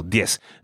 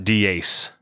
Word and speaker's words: diez: And the last number is diez. Just one syllable. – Okay, just one syllable. Diez. Not diez, right? – Diez. – Diez. Number 10.